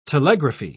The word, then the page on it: telegraphy: British English